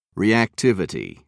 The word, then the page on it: reactivity: American English